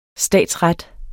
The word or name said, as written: statsret